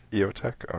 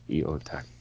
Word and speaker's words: EOTech: Now, what is the actual proper pronunciation of that? – Eotech. – Okay. – Yeah. I can play the voice snip. He repeated it several times. Eotech.